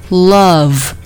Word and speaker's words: love: 下嘴唇要接触上牙齿底端去发这个 v love 不要读成 love 啊。